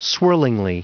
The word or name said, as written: swirlingly